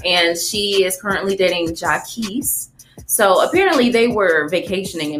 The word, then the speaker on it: Jacquees: I pronounce Jacquees.